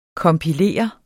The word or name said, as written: kompilere